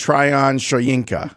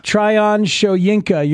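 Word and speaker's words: Tryon-Shoyinka: Joe Tryon Shoyinka. – Tryon Shoyinka.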